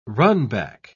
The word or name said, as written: runback